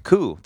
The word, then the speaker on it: coup: And if you have whatever they would call it, like a section of the coup, I don't even know how to say it. – It's coup. – Coup.